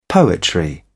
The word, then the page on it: poetry: British English